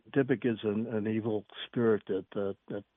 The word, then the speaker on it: Dybbuk: Well, there's another word in your book that appears quite frequently, and is the correct pronunciation Dybbuk? – The Dybbuk, yeah.